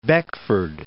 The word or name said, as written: Beckford